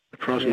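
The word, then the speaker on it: Coprosma: C-O-P. – Yeah. – P-R-O-S-M-A, Caprosma. – Caprosma.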